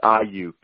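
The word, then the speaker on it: Aiyuk: Now, I'm going to butcher the name probably, but what are your thoughts on Brandon Ayuk from Arizona State? – Yeah, so it's Ayuk. – Ayuk. Not even close.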